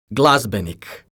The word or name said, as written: glazbenik